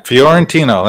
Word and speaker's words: Fiorentino: But it's Mark Fiorentino? – Yeah, Fiorentino. That's very good.